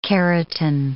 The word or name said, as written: keratin